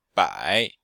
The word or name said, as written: bai3